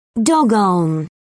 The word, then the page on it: doggone: British English